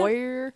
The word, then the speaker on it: Oyer: Oyer and Terminer. – It's like oyster, but without the st.